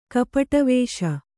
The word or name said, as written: ಕಪಟವೇಷ